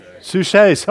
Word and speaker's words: Suchet: And David Suckett, he's an actor. I don't know, is that how you pronounce his name? Suchet. David Suchet.